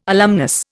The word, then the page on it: alumnus: Indian